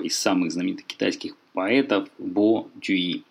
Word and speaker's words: Бо Цзюй-и: На самом деле, конечно, его китайцы читают Бай Джуи, но по-русски у нас еще с 19 века сложилась традиция двух поэтов, чье фамилия пишется иероглифом Бай, читать старым чтением Бо, которое, кстати, можно найти в словаре Палладия.